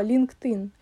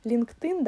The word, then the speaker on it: LinkedIn: Во, LinkedIn. LinkedIn, да, он произносится.